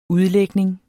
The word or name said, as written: udlægning